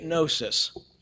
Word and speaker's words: gnosis: Now, there's a silent G on the beginning of that word. You look at it and it looks like gnosis, but they say it gnosis.